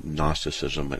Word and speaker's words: Gnosticism: Gnosticism is a philosophical view that deals with knowledge, from the Greek gnosis, which Gnosticism, in English, the G is silent.